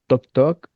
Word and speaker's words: Talk Talk: Carlos, desde Curarrehue, nos pide la banda Talk Talk, que podría pronunciarse mejor como Tok Tok, algo así más en inglés. Pero se escribe Talk Talk.